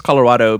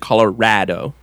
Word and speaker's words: Colorado: Right, not just Colorado, but you want to make sure that you pronounce the R-A-D in the middle of it, Colorado. – Colorado. – Yeah, okay. Because it's a rad fucking place.